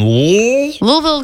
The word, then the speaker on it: Louisville: Oh, Louisville. – Louisville? – Louisville. – Oh. – Louisville? Louisville? – Yes, it's in Louisville, Kentucky.